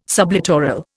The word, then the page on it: sublittoral: Indian